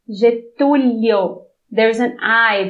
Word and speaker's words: Getúlio: You didn't pronounce the I. You just went. – You said Getulio, and I said Getulio. Do you hear the difference? – I don't think you said that originally.